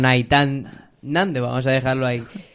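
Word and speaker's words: Nahitan Nández: Naitán Nández. Naitán, perdón. – Eh, bueno, creo que se pronuncia así, por lo menos cuando yo veía partidos de él, lo pronunciaban así.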